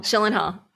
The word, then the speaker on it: Gyllenhaal: Someone suggested online, not me, that I should be played by, I hope I pronounce it right, Jake Gyllenhaal. – But, Gyllenhaal.